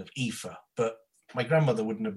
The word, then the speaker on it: Aoife: My name is Aoife, Mrs. Classy. It's Aoife.